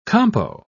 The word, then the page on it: compo: British English